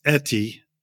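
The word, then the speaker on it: Uitti: and it's pronounced Etti, but it's U-I-T-T-I.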